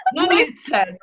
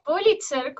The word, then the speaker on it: Pulitzer: Pero creo que es Pulitzer ¿o no? No se dice, no hablo este idioma yo. – no, es, Pulitzer. – No sé. Sí, es Pulitzer, como algo así.